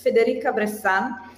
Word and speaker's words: Federica Bressan: So if you Google my name, which uh, I love the way you said it, and I gave up on getting it right, but just for the record, it's Federica Bressan.